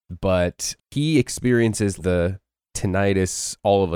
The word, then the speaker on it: tinnitus: Maybe you want to have tinnitus? Is that how you pronounced it earlier? Because I always say tinnitus, and I guess that's wrong. – Tinnitus, tinnitus, tomato, tomato, doesn't matter.